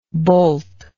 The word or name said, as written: bolting